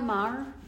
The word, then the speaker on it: Maher: You know, remember Bill Maher? M-A-H-E-R. – Yeah, the atheist, uh-huh. – How do you pronounce that name? – Maher. – Maher.